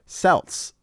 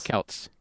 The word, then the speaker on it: Celts: Celts. – This says Celts. S-E-L-T-S. Celts. It's not the Celtics. It's the Celtics. – No, no. It's still pronounced with a hard C.